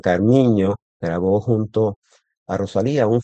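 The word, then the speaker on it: Carminho: Carmiño, que la ñ en portugués se escribe NH, Carmiño.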